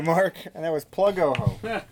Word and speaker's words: Ojo: Yeah, the Oho Customs. Yeah. – Spell that for them. – O-J-O. – There you go. You don't want them to use an H.